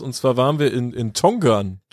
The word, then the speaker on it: Tongeren: Es gibt beide Schreibweisen da irgendwie, und so ganz haben wir nicht aufgedeckt, wann man welche nutzt, aber ich würde jetzt einfach mal niederländisch Tongeren aussprechen und nicht Deutsch. – Genau, wir nennen es einfach Tongeren, und in Tongeren steht tatsächlich ein Lost Ground.